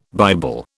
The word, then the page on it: Bible: British English